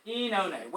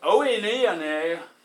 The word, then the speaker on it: Oenone: Enone. Whenever you see two vowels together, this is called diphthong in Greek, you just pronounce the second one. So it looks like oenone. Oenone? Oenone? It's enone. Enone.